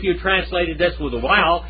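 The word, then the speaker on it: vav: Now I learned this next letter as wow, not vow. Okay, wow. Because wow is the Greek pronunciation of this word. Wow. Okay? But they now pronounce it, the Hebrews now pronounce this word as vow. That is this word right here. Okay? That one right there.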